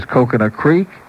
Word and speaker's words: Coconut Creek: Here's Coconut Creek. Rhymes with geek.